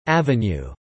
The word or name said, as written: Ave